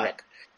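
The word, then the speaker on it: Schreck: Bien, para empezar, sería un clon de Sina, no sé cómo se, dice, se escribe con Z, Sina Lavey Shrek. – Sí, se dice ah. Shrek.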